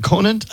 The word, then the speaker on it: Conant: Is it Conant? I always say it wrong.